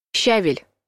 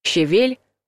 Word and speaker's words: щавель: Щавель — правильный вариант. Щавель — кислый, зеленый, полезный и, по мнению некоторых, очень вкусный. Осталось научиться правильно его произносить, а правильно будет с ударением на втором слоге — щавель.